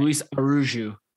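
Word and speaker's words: Araújo: Luis Araujo, not Araujo. That's how you would say Julian Araujo's name, because he is right. Mexican. Luis, even though it's spelled the same.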